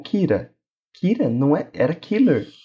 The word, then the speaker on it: Kira: Então, killer, aquele R no final, Some. Então vira Kira.